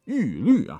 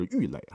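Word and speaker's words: 郁垒: 树上有二神掌管，一个叫做申叔，一个叫做玉律啊。这两位大神的这个读音要专门去查，否则的话，您可能会念成神荼或者玉垒哈。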